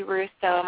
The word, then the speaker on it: Russo: And they could also search for me on Facebook just under Stacy Russo.